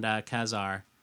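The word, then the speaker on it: Ka-Zar: Other Marvel titles such as Marvel, Fanfare, and Kazar? How do we say that? Anyone? – I've always pronounced it Kazar.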